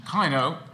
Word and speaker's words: Kino: Kino, like, not like the German for cinema, but Kino, short I.